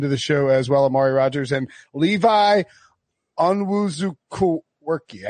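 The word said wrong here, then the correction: Onwuzurike: I didn't get that right. Wilson, how do I pronounce it? – Owen Sirike.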